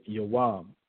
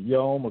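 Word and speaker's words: yawam (יום): All right, the word there for day is the ancient Hebrew word yawam. All right, some would pronounce it yom according to modern Hebrew. According to ancient Hebrew, it would be pronounced yawam.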